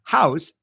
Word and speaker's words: Haus: Now, it's the German spelling of BC Design House. And so that's BC Design House, H-A-U-S. Is it pronounced differently? Is it just pronounced? – It's pronounced exactly the way you said it, the German way.